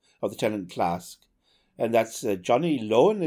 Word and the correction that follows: Ronan: is it? What? I can't get, I never get his name right. – Ronan. – Ronan, Johnny Ronan.